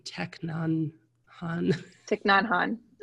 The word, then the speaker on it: Thich Nhat Hanh: Yeah, there's the book Fear, and I can never pronounce his name, Thich Nhat Hanh.